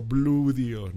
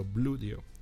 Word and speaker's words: Bluedio: O Bluedio, ¿no? Que esa es otra forma de ponerlo. Se escribe Blue como azul, Bluedio, todo junto, Bluedio.